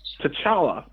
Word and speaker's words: T'Challa: And it focuses on the king of Wakanda, and his name is T'Challa. T'Challa is how you would pronounce his name.